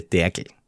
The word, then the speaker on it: Dergl: sind, und äh, da muss, das muss ich österreichisch aussprechen, meine Familie nennt das Derkel. – Derkel? – Derkel. Also D-E-R-G-L, Derkel.